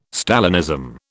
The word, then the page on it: Stalinism: British English